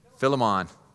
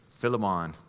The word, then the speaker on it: Philemon: Philemon. Philemon.